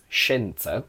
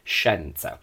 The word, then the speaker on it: scienza: Going back to what I said about accents in Italy, I pronounce scienza, which means science, with a closed I, because I'm from the north of Italy. In a technical perfect diction, and in the south, centre and south Italy, they pronounce it with an open e, So they would say scienza. Now, the difference between scienza and scienza, so e and e, is not phonemic in Italian.